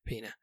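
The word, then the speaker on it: Peña: And it's got Edward Norton, Kate Winslet, Michael Pena or Pena? How do we say his name? – Pena.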